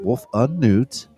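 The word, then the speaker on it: Wolfenoot: Wolfenut, pronounced Wolfenut, yes, came into being after a 7-year-old New Zealand boy told his mom about the holiday that he had just created.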